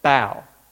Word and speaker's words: Por: And this young lady, her name's spelled P O R, but actually I believe it's pronounced Bow.